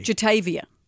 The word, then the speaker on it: Jatavia: City Girls are Jativa Johnson, mm-hmm. known as JT. – Yeah, of course. – And Carisha Brownlee, known as, obviously, Young Miami. Young spelt Y-U-N-G. – Jativa's spelt with a J. – Jatavia.